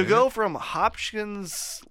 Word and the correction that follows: Hodgkin's: lymphoma. – Yeah. – Is that how you say it? – Hodgkin's lymphoma. – Yeah, right. Hodgkin's lymphoma.